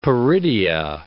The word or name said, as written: peridia